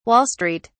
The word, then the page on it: Wall Street: British English